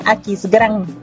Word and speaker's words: Aquisgrán: Puedes decirlo AGE, ajá, o en español Aquisgrán. – ¡Órale! Aquisgrán en Alemania, señores.